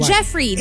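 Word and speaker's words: Geoffrey: Yung kanya kasi is like J, J-O-F-F. So, Joffrey. Pero, you know how like... There, another spelling of Jeffrey is actually G-E-O. – Yeah. – G-E-O-F. – But it's pronounced Jeffrey, diba? – It's pronounced Jeffrey.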